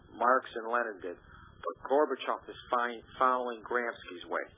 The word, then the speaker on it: Gorbachev: Am I right in pronouncing his name? – Gorbachev.